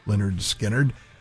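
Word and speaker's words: Lynyrd Skynyrd: Rock 103 Skinnerd, from pronounced Leonard Skinnerd.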